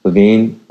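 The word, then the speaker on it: Lavigne: And why is Avril, I don't even know how to say her name, Lavigny? How do you say her name? – Levine.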